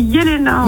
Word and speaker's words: Jelena: Non, en fait, c'est Yelena. – Alors, je demande juste avant. – C'est moi. Alors, c'est ma faute, c'est que moi. – Eh ben, non, merci. – Non, mais, non. – Je vais vous expliquer, Yelena, parce que je demande à Louane juste avant. On prononce Yelena ou Jelena? Elle me regarde, elle fait Jelena en faisant un J avec ses doigts. – Pas comme Joule.